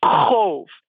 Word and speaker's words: qov: Oh, by the way, your Klingon name is, it's spelled Q-O-V. How do you pronounce that? – It's going to sound like a wind noise again. Cove. I say it rhymes with stove, except the first sound is like you're choking on spinach.